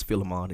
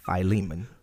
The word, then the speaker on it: Philemon: And some folk grew up thinking it was Philemon. It's pronounced Philemon is his name.